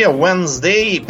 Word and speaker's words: Wednesday: что в э, английском языке Wednesday, причем это произношение не, от, не отражает написание.